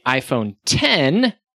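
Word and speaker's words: iPhone X: They also announced the iPhone 10, spelled X, because you pronounce 10 like X. No, you pronounce wrong. – X like 10.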